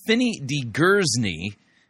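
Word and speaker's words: Fini de Gersigny: We're going to listen to Fini DeGersigny. Apparently, that's how you pronounce the name. I've been told it's of South African origin,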